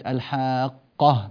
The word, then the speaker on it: الحاقة: الملاحظة الثانية القاف في كلمة الحاقة، ما الحاقة، هذه القاف مشددة فلا تنطق بها مخففة فتطلع معك الحاقة ما الحاقة، قريبا تصير الحاقة ما الحاقة، هذا خطأ، خطأ وكبير جدا، فانتبه له لأنه يعني يذهب بحرف من حروف كتاب الله بسبب الذهاب بالشدة.